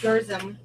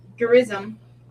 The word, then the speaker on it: Gerizim: Gerizim, Gerizim, Gerizim spell it? – G E R I Z I M.